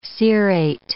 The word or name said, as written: cerate